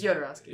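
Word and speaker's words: Jodorowsky: I don't actually know how to say the guy's name. I've been saying it's Yodorovsky. – It's Yodorovsky. Okay.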